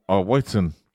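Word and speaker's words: Wighton: Oh, speaking of Dolphins, that's where, so is it Jack Whitman? How do you say his name? Whiten.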